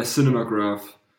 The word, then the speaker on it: Cinemagraph: Ja, Cinemagraph.